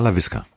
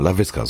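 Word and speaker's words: Laviska: Ja, Lavisca, genau, Lavisca, so, so wird's ausgesprochen.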